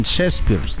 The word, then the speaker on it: Shakespeare: En Shakespeare. – Ok. – ¿Lo dije bien? – Pues ok. – Muy bien.